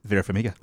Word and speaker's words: Vera Farmiga: Vera Farmiga.